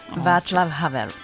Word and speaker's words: Václav Havel: Comment on prononce Eva ? – Václav Havel. – Ah, j'étais pas loin. J'ai dit Václav et c'est Václav Havel.